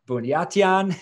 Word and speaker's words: Buniatyan: And today we're here with David, and I'm going to butcher his last name, Buniatyan. Was that good? – Nice job. – Yes, nailed it.